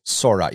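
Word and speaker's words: Sor Talgron: Captain Sorto Chur of the Word Bearers. Sorot, you're right, Sorot. Captain Sorot,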